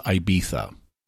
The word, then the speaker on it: Ibiza: I looked up how to pronounce it, and there are several different ways. The way they do it in the tape or in the recording is Ibiza.